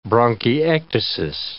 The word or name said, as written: bronchiectasis